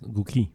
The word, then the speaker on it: Gucci: Gucci, I think it's pronounced. – Yeah, exactly. – It's Gucci. – The C is hard. – I thought it was a Q. – It's a hard C.